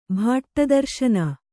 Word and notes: ಭಟ್ಟದರ್ಶನ: kannada

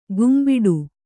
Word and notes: ಗುಂಬಿಡು: gumbiḍu

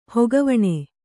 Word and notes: ಹೊಗುವನೆ: hoguvane

